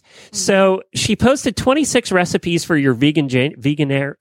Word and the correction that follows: Veganuary: How do you say it? – Veganuary.